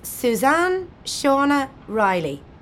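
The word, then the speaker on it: Suzanne Seana Reilly: It's Suzanne Shauna Riley. I'll spell it for you. It's S-U-Z-A-N-N-E S-E-A-N-A R-E-I-L-L-Y.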